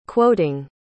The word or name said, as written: quoting